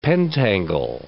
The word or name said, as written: pentangle